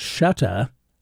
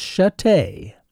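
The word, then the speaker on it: Shutta: For years, I mispronounced her name as Chatte until it was pointed out to me that the correct pronunciation was Chute. But that, too, is incorrect. I know this because I found a recording of a radio commercial in which Ethel introduces herself. – Hello, everybody. This is Ethel Chatte. – So, the correct pronunciation is Chatte.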